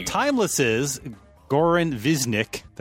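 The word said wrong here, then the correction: Višnjić: Am I saying that right? – Nope. Visnish.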